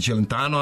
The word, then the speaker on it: Celentano: Celentano jeste, izgovara se Celentano, ali smo rekli Underworld nije Underworld,